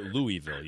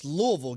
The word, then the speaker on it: Louisville: He also called it Louisville. You hear that? – Yeah, it's Louisville. Get it Louisville. Right. That's why I know you went to damn SC. Can't even talk. Can't even say the name right.